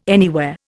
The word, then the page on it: anywhere: Indian